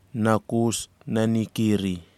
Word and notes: nakus nani kiri: kwamera

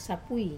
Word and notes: sapui: Wamesa

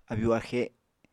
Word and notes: abyu-axe: Apatani

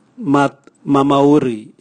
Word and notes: matmamauri: Raga

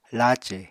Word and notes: lace: Apatani

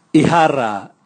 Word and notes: ihara: Raga

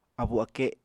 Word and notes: abu-ake: Apatani

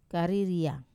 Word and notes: kariria: wamesa

